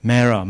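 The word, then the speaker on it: Marah: And the place is called Mara, Mara, I pronounce Mara.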